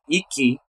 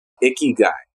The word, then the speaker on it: ikigai: Or I'll say the syllables. Ikigai. So, icky guy, but icky.